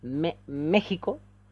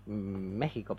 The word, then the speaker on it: México: Y con X, además. Por eso el país se llama México. Y ese se debería México. – pronunciar México, pero bueno.